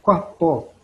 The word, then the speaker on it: quapó: É quapó. É como se fosse um, um quê. Quapó. E os falantes da língua krenak, a avó Laurita, falante é, da língua materna, ela tinha um jeito de pronunciar essa palavra que, como ela não é escrita, ela ficava, é, a fonética dela ficava parecendo quá, Quapó.